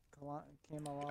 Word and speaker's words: Kamala: I don't know about Miss Kalam, or how do you pronounce her name? Kamala. – I think it's like Kamala. It's Kamala. Something like non American.